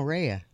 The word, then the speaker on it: Rhea: Isn't her name Rue? – Rue. – No, Rhea. – Rhea Pearlman. – How do you spell it? – R-H-E-A.